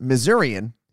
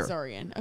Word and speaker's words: Missourian: Just so you know, it's pronounced Missourian here. – Missourian. Okay, that makes sense.